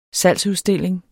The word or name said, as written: salgsudstilling